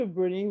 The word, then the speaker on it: Abroni: And so when Anna journeys to Bamana, she experiences the opposite of that maybe for the first time, which is, again, correct me if I mispronounce, but she's called an Abroni, which means white woman in the language. Tell me about yes. that. Yes. Yeah, she's called Abroni, which means, yeah, white person, just generally.